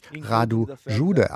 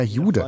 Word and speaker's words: Jude: Mein Name auf Rumänisch spricht sich Radu Jude aus, aber im Deutschen wäre das ja Jude.